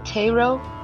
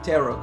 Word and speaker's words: tarot: Did Brace just pronounce it tarot? – Tarot card. – Thank you. – Like carrot.